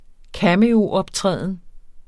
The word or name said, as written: cameo